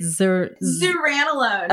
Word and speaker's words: Zuranolone: Zuranolone.